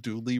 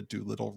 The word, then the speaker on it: Doolittle: Not the Dooley run, the Doolittle Raid. – Did my microphone cut out? Did you miss a syllable there? I'm sorry. I didn't catch the question. Doolittle. Yeah, it's just a dull in there.